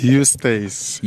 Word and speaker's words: Eustace: The guest that we have today, like I've said, I'm sitting with the acting CEO of Proudly SA, Mr. Ostas Mashimbe. I hope I pronounced that correctly. – It's Eustace. – Eustace. Oh, Eustace Mashimbe.